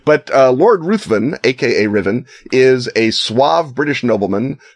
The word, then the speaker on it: Ruthven: And I had the glorious experience at Dragon Con of being corrected in my pronunciation of Lord Ruthven by none other than Chelsea Quinn Yarbrough, who says it's pronounced Riven.